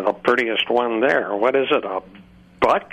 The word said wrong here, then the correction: Buick: I couldn't even pronounce Buick.